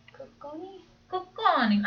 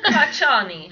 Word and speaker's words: Cocconi: Cocconi. – I got it. 1 second. Giuseppe Cacciani.